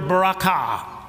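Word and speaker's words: berakhah: This word blessing is the Hebrew word barakah. The accent is on the last part of the word.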